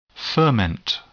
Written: ferment

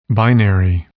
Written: binary

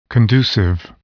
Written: conducive

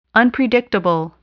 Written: unpredictable

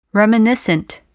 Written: reminiscent

